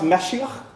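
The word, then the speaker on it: Mashiach (משיח): Hebrew word here, anointed one, is pronounced Mashiach in Hebrew. It's where we get the English word Messiah.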